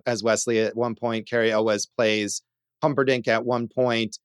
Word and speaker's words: Cary Elwes: but Kerry Ulwes, if that's how you say it, Josh. – Yeah, you definitely say it with that very exaggerated.